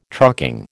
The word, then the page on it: trucking: American English